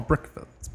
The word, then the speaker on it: breakfasts: We have several special events scheduled, including Wednesday night dinner, hospitality suite, pizza party, special breakfasts. How do you say that? Breakfasts. – Breakfast. – Is that how you say it? – Special breakfasts. – Oh, breakfasts. Breakfasts. – Breakfasts. – That's hard, y'all.